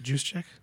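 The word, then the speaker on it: Juszczyk: Is it Juice Check? – Juice Check. – Juice Check? – Yeah, you said Juice Checker.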